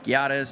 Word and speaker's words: Giada: Plus, we are dining at Giada's, so I can tell you definitely how they pronounce it. He actually said it's a soft G, like jihad.